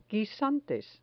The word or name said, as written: guisantes